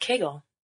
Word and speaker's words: Kegel: And we're going to call it Kegel. – Because it rhymes with bagel.